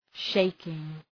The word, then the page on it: shaking: ‘ʃeıkıŋ